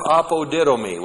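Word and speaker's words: apodidomi (ἀποδίδωμι): Will recompense is the future active indicative of the verb apodidomi, which looks like this in the Greek. A-P-O-D-I-D, long O-M-I, apodidomi. Apodidomi is a compound word made up of the preposition apa, away from, and the verb didomi, to give.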